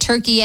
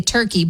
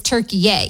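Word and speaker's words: Türkiye: But we'll see what happens tonight in their game against, I guess now we say it, Turkey, eh? I always say Turkey, but the official pronunciation of the country that the U.S. is playing tonight is Turkey.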